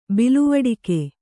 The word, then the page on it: ಬಿಲುವಡಿಕೆ: biluvaḍike